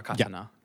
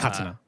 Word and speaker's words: katana: Katana, and that's how it's pronounced, Tyler. Katana. Yeah. – Katana.